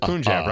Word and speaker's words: Punjab: I'm saying that right. That was Punjab, right? – Yeah.